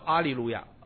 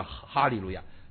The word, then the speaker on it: 哈利路亚: 哈利路亚，他说这个哈利路亚说错了，应该原文叫阿利路亚啊，所以以后大家都不叫哈利路亚，叫阿利路亚。其实呢，他错了，因为哈利路亚在希腊文读音的时候有一种特殊的读音，就是专有那个符号的时候前面要加个 H，就是呵，所以那个不叫阿利路亚，叫哈利路亚。